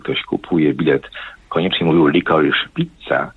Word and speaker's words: Licorice Pizza: Licorice Pizza. Ja specjalnie wymawiam tak, jak to robi Alana Haim, która gra jedną z dwóch głównych ról.